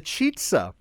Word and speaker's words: Chizza: And it's called the Chizza. – All right. – Now, it's spelled C-H-I-Z-Z-A.